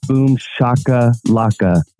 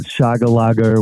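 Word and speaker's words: boom shakalaka: It's boom shaka laka, not shaga laga or whatever you're saying.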